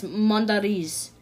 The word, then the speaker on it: Mondariz: It's from Spain, so yeah, it's Mondariz.